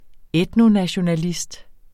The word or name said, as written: etnonationalist